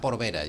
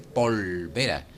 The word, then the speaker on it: polvera: anécdota que hay de Lola Flores con la camarista cuando dice, niña, tráeme la porvera. Dice, no, Lola, se dice polvera.